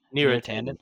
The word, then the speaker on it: Neera Tandon: Neera Tandon.